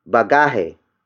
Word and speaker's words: bagahe: The next word is bagahe. Bagahe. Bagahe. Bagahe is spelled as B-A-G-A-H-E.